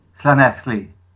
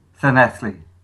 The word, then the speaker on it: Llanelli: Did I pronounce Llanelli correctly? – No, it's Llanelli. – Oh, gosh. Okay. No, we like it. Can you say that again? Sorry. – Llanelli.